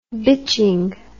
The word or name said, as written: bitching